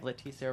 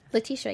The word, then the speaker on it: Letitia: We have Letitia Wright. Did I say her name right? – Letitia, yeah.